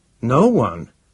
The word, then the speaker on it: Nowan: "No one?" said William explosively. "No one. N-O-W-A-N," the matron spelled it. "He chose it last year." "And it meant nothing to you? It is pronounced No One.